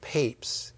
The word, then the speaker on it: Papez: and it's called the circuit of Papes, even though it's spelled like Papez, P-A-P-E-Z, and that's a memory circuit.